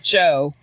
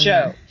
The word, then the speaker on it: Gio: Well, they pronounce it Joe. The G-I-O is one sound. – Giovanna. – Joe.